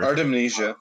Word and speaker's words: Artemisia: I don't think I'm saying her name right anytime, but whatever. – Artemisia, yeah.